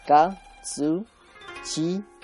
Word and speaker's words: Katsuchiyo: Tiene cuatro sílabas, ¿vale? Katsuchiyo, con Y, ¿vale?